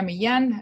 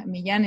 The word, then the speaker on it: Millan: Millan is M-I-L-L-A-N, just like, you know, the dog whisperer, spelled the same. It's the same last name. You know, people in the U.S., they always call me Carolina Millan, but the double L is sounds like that.